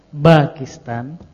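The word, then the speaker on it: Pakistan (باكستان): Pakistan.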